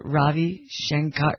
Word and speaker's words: Ravi Shankar: Norah Jones, the singer, is Ravi Shankar.